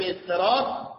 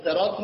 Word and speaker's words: صراط: این کلمه استراط عرض کنم توی نماز هم حالا از جهت فتوایی دنبال کنید، صراط رو سادش رو میشه به صورت سین تلفظ کرد.